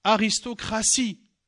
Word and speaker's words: aristocratie: Euh, une partie, T-I-E. Aristocratie, T-I-E, mais je prononce S. Euh, exécuter, exécution. Exécution, on l'écrit comment T-I-O-N à la fin. T-I-O-N.